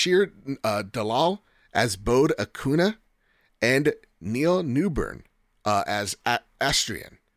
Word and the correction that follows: Astarion: from Baldur's Astarion. – Gate. Sorry, Astarion from mm-hmm.